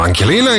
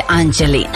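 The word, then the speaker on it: Angelina: Ankelina ili Anđelina, ja to uvek pogrešno izgovorim. – Pa, piše se Ankelina, čita se Anđelina.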